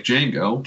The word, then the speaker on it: Django: Django sits at the bar, and he's sitting next to a cowboy dressed like Django, played by Franco Nero. And he says, "What's your name?" He says, "Django. The D is silent."